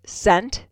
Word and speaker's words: scent: Which letter is silent in the word scent, as in fragrance, a scent? Is it the S or the C? – I think it's the C.